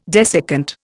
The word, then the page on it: desiccant: Indian